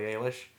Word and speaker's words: Eilish: It's Eilish, but the Google Home tells me that it's Billie Eilish, not Eilish. So I'm going to go with Eilish.